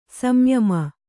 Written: ಸಂಯಮ

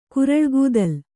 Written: ಕುರಳ್ಗೂದಲ್

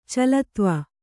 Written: ಚಲತ್ವ

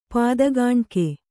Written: ಪಾದಕಾಣಿಕೆ

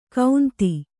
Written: ಕೌಂತಿ

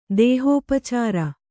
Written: ದೇಹೋಪಚಾರ

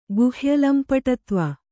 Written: ಗುಹ್ಯಲಂಪಟತ್ವ

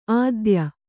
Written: ಆದೇಯ

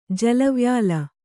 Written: ಜಲವ್ಯಾಲ

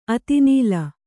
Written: ಅತಿನೀಲ

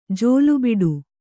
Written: ಜೋಲುಬಿಡು

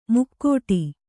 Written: ಮುಕ್ಕೋಟಿ

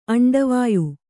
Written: ಅಂಡವಾಯು

